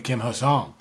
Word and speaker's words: Kim Ha-Seong: kind of like Ha-Seong Kim, or excuse me, Kim Ha-Seong, my boy. – That's right. We were corrected on how to pronounce the name, and now we've been corrected on the order of the names to be said. So Kim Ha-Seong is apparently the correct way to say it.